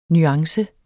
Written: nuance

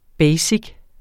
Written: basic

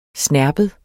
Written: snerpet

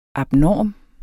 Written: abnorm

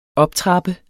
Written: OPTRAPPEDES

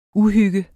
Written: uhygge

